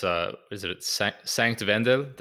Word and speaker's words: Sankt Wendel: It's Sankt Wendel? How bad did I butcher that pronunciation? – Yes. No, it's good. It's good. It's a very good pronunciation.